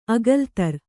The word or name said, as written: ಅಕಲತ್ರ